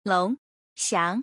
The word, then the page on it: 龙翔: lONGXIANG